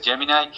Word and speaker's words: Gemini: O, por ejemplo, Google tiene sus propios desarrollos en inteligencia artificial, como es Gemini, o como se le pronuncia en inglés, Gemini,